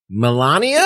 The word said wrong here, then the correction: Melena: It's Melena. It's not Melania.